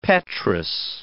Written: petrous

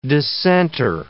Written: dissenter

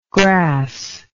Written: gras

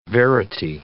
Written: verity

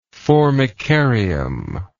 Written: formicarium

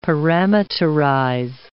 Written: parameterize

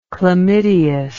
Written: chlamydeous

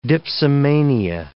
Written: dipsomania

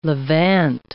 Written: levant

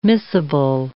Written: missable

